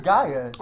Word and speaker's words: Gaia: I think it's Gaia. – Oh. – Like the goddess from Captain Planet.